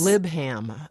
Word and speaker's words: Leibham: Well, they actually pronounce their own name on my website, which is really good, because when you look at Joe, L-E-I-B-H-A-M, how would you pronounce that? I guess Libham. – I know there's so many options here, but the way he says it, it sounds as though there's a P in his name.